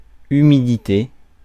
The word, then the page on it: damp: [ˈdæmp]